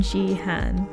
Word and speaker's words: Jihan: I'm Jihan.